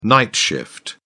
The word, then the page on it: night shift: British English